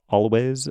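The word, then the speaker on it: Alvvays: It's pronounced Always, Always, whatever, but it's spelled with a W. It's spelled with two V's, A-L-V-V-A-Y-S, which is reminiscent of Chiverches, another band that I like, and they're not totally dissimilar.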